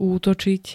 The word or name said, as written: útočiť